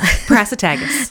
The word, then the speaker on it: Prasutagus: Prasutagus.